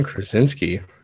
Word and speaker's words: Krasinski: John Krasinski.